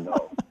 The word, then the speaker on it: Nels: It's Nels. Rhymes with smells, but okay.